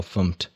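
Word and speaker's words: fmt: And what we've seen languages with, you know, official implementations adding formatter tools to the toolkit for that exact reason, like Go, FUMT, as they like to pronounce it, the Go formatter.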